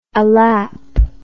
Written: alap

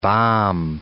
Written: balm

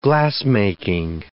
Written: glassmaking

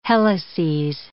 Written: helices

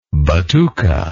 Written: batuque

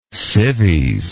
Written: civies

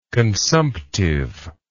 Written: consumptiveness

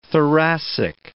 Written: thoracic

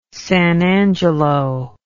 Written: san angelo

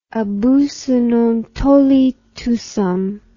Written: abusus non tollit usum

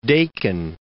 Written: Dakin

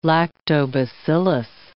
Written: lactobacillus

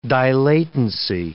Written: dilatancy

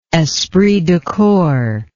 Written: esprit de corps

